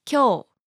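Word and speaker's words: kyō (今日): Another one is kyō, meaning today. However, when you pronounce the E sound and say kyō, it means handy or skilled.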